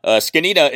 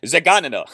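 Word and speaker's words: Zgonina: Skinnina is spelled Z-G-O-N-I-N-A. I guess Skinnina sounds better than Zaganina.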